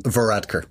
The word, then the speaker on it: Varadkar: How do you say his last name? – Varadkar. – Varadkar.